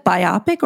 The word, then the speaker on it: biopic: is it biopic or biopic? – Biopic. – Okay, I wasn't sure.